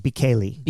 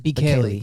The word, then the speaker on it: Bekele: His last name? – Bekele. – Bekele.